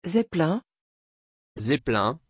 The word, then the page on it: Zeppelin: French